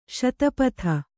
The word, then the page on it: ಶತಪದಿ: śatapadi